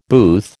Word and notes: booth: American English

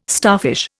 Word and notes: starfish: Indian

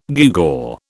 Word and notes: gewgaw: British English

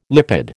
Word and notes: lipid: American English

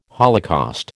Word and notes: holocaust: American English

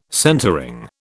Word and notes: centreing: British English

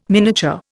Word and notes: miniature: Indian

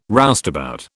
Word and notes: roustabout: British English

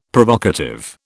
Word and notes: Provocative: British English

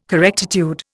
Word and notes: correctitude: Indian

